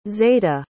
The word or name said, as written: zayda